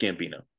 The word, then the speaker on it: Gambino: So some people are like Gambini or Gambino or it's like, no, it's just Gambino.